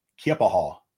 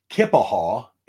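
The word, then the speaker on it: Keya Paha: I've heard Kea Paha. Some folks say it's Native American. I don't know exactly which tribe up there, but it's Kea Paha. Or Kipaha is the white version of that. So it's somewhere in between Kipaha and Kea Paha. Kea Paha is the way some people say it.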